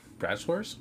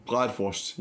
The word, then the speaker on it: Bratwurst: I'm making a bratwurst. – Bratwurst? What? – Bratwurst. Yeah, the German sausage. – Okay. So, bratwurst. – So that's how you say it in German.